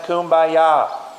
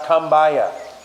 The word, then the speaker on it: kumbaya: Attention campers, it's pronounced kumbaya, not kumbaya.